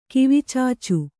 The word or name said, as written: ಕಿವಿಚುಚ್ಚು